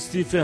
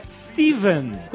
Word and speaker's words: Stephen: Gostaria de apontar que a correta pronúncia do nome do físico cadeirante e swingueiro é Stephen, com a grafia PH, não Stephen, como a gente falou. A grafia PH é apenas uma versão antiga do nome escrito com V, Stephen. A pronúncia nos dois casos é a mesma, com V.